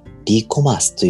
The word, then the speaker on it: REコマース: 皆さん、 e コマースという言葉は聞いたことがあると思うんですが、では、リコマース、 re コマースと書いて、リコマース